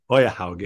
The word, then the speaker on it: Øyehaug: Øyehaug. Yeah. Øyehaug, yes.